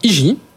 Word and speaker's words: Heegee: Alors, IGI. C'est une start-up qui propose... – Ça s'écrit H-E-E-G-E-E.